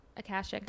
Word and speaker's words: Akashic: Akashic.